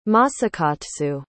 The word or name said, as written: Masakatsu